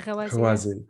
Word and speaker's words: Gawazi: And that, but what else is also lost as a part of that is the history of the Gawazi. Am I saying that correctly? – Yeah, Gawazi.